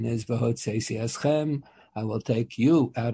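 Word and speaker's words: Hotzeisi Eschem (הוצאתי אתכם): And the Pasuk has the expression, Hotzeisi Eschem Mitachas Sivlos Mitzrayim, that's the correct pronunciation, Hotzeisi Eschem.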